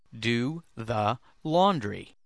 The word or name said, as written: do the laundry